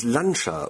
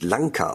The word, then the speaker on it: Lancia: Für die Sprachfreunde, das I hinterm C sorgt dafür, dass es Lancia und nicht Lanca ausgesprochen wird.